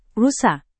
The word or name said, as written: rusa